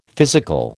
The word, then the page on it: physical: American English